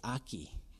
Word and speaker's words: ackee: And I remembered how to pronounce that because my kids would say when they were younger that if they didn't like it, it's ackee.